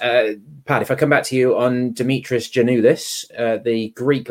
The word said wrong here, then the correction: Giannoulis: I think you'll find it's Giannoulis. And we only know that because we had this on Friday and we had a Greek native speaker put us right, Connor? I think, yeah, on our Friday show. – They were lauding Pad's pronunciation of it. Mine not. No, there you right. Go. That's apparently, ask on anything apparently, Greek. Y, yeah, apparently the G-I-A is pronounced Y, but that's about the end of my linguistic skills, so I won't be telling anybody how to pronounce anything.